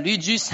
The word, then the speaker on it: ludus: But also, the ludus has a bit of erotic eros in it. You know, ludus, that's how you say it in French.